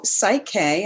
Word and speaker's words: Psych-K: So Psych-K, and it's spelled, for those who don't know, it's P-S-Y-C-H-K. So psych as in the psyche, and it stands for key to the psyche.